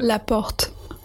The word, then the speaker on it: Laporte: And I guess I don't pronounce that as well as Bruna does. – Go on, how do you say it? – Laporte.